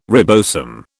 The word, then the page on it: ribosome: British English